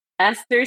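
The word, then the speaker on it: Eszter: But Esther spelled differently. So spell it for us. Let's hear it. – It's E-S-Z-T-E-R.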